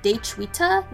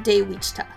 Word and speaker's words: Daechwita: Additionally, August Dee's song, which was the inspiration for Chun Myung-hoon's Myung-hoon Is Going, is pronounced Daechwita, not Daewichta.